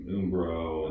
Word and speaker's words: Umbro: I mean, I love looking at the Premier League jerseys, because between Umbro and, you know, Adidas and Puma and you just pronounce with a long U. Umbro.